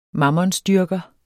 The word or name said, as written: mammonsdyrker